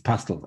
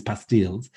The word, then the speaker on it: pastille: I said, it's pastilles. And I'm looking it up going, and it's on the website of Roundtree going, well, pastille was obviously named after the French word pastille, which rhymes with Bastille, right?